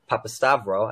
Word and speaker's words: Papastavrou: With all of that said, today we are joined by Nicole, Papastavrou. I said that right, right? – Yes, you did.